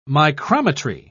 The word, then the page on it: micrometry: British English